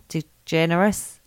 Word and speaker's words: DeGeneres: DeGeneres.